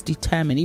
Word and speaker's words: determine: And then it's determine, not determine. It's determine, even though it ends in M-I-N-E. It's pronounced determine.